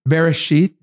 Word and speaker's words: Bereshit: Bereshit, did I say that right? – Yeah, yeah.